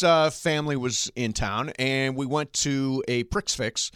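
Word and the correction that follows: prix fixe: which, you know, is where all the courses are laid out. – Prefix. – Oh, prefix, sorry. Well, it's spelled Prix Fix.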